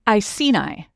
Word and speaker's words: Iceni: Iceni.